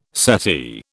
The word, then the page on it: settee: British English